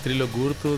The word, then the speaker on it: Trilok Gurtu: Y vamos a la segunda recomendación que nos traes de música, que es Trilok Gurtu, perdón, no lo pronuncio bien. – Es Trilok Gurtu, de la India.